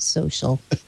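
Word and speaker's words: So.cl: It's social without an I or an A. – But they still pronounce it social.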